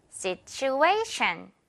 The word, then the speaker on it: situation: situ 한 다음에 ation 이 A 부분에 우리 세 번째 블럭에다가 강세 줘야 돼요. situation 이렇게요.